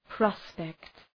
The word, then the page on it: prospect: ‘prɒspekt